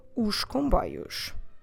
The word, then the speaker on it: os comboios: But it doesn't work to say os comboios. So in this case, you really must have the sound os that S being pronounced like a sh sound. Let's imagine in English SH, os, os comboios.